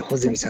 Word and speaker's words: 八角: 8 に八角形の八角って書いて穂積さんって読むんですからねも